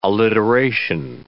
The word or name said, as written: alliteration